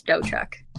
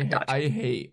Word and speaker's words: Dotchuk: So it's Emma Jane and the T is silent, so it's Dochuk. It's not Dotchuk.